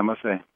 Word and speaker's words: Masse: But his name is Guido Masse. Is that how you say it, Guido? – Yeah, Masse.